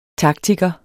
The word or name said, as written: taktiker